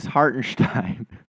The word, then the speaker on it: Hartenstein: But then I put it to the other group that hadn't canceled me, and I asked, and they were like, well, he's German, and that's his that's how you pronounce Hartenstein in Germany.